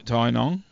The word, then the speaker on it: Tynong: Well, what's it called again? Tynanum or something? – Tynong. – Sorry? – Tynong.